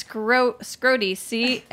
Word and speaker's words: Scrotie: It's scroty. S-C-R-O-T-I-E.